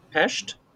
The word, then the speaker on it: Budapest: No, ScarJo's actually pronouncing it correctly. – Is she? Okay. – Pest? – Yes.